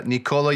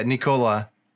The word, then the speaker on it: Nikola: Yeah, because when you say it fast, yeah, Nikola. – No, it's not Nikola. You're putting the emphasis on the wrong vowel.